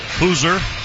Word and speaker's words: Hauser: Hoosier, spelled Hauser. For our listeners. – Yes, not pronounced like it's spelled.